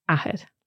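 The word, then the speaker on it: athair: And you're going to see why we need to distinguish between the two here, because the word for father is áthair. – Áthair. – Which is a short A, there's no accent on the A there, so áthair.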